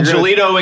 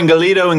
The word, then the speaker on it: Ingelido: So Ingelido, Ingelido, Ingelido, whatever you want to say.